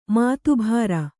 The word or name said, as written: ಮಾತಬರ